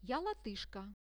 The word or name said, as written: ja-latyshka